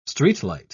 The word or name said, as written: streetlight